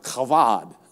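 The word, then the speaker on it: Chavad (כבוד): It's a word in the Old Testament that's spelled, and you would say it kind of like spelled Shabbat, but that's not how you say it in Hebrew. It's kind of like Chavad.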